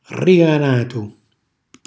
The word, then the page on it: rriganatu: calabrese